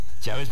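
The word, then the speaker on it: Chaves: Chávez, pero este se escribe con S.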